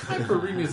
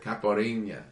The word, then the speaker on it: Caipirinha: Kai Pariñas is such a weird way to pronounce that drink. – What is it? – Caipirinha. – Kai Pariñas.